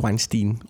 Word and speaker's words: Weinstein: Men jeg bliver simpelthen nødt til at stoppe det nu og sige, det udtales Weinstein.